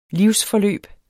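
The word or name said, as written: livsforløb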